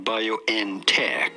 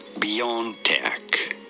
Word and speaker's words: BioNTech: No, it is not BioNTech, but rather BioNTech.